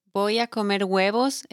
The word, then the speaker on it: huevos: Because it's huevos, it's with H. The H in Spanish doesn't sound, so just huevos.